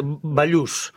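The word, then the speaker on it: Ballús: Neus Balas. Bon, très mal, exactement. C'est ça. Bon, bah, tant terriblement, c'est Bayous. Bayous. Bayous. – Bah, très bien, Bayous.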